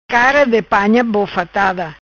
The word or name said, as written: Bofatada-Cara Di Panha